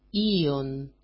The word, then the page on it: ion: i-on